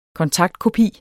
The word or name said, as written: kontaktkopi